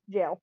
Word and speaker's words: gaol: G-A-O-L. – Gaol is jail. – It's trial at the... – Yeah, gaol is pronounced jail.